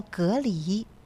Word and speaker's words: 蛤蜊: 其实它的正确读音呢叫蛤蜊啊，蛤蜊，但是咱们北方人啊，尤其锦州人就是嘎了啊。